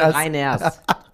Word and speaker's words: Reiners: Da hast du meinen Namen auch falsch gesagt. – Till Reiner? – Reiners. Till Reiners.